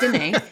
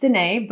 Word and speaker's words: Danae: And I'm going to just like stab myself in the foot here, but it's Danae, not your fault. – Oh, I'm sorry. – It's Danae,